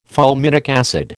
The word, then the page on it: fulminic acid: American English